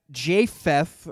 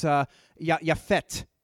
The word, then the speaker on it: Japheth: But you have Shem, you have Ham, and you have Japheth, or I think that's how it's pronounced in Hebrew. It's Yafet.